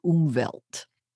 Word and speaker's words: Umwelt: And the word is Umwelt. – Umwelt? – It's German. Yeah, it's spelled U-M-W-E-L-T. Okay. So it's always going to be italicized in English, and it's spelled U-M-W-E-L-T.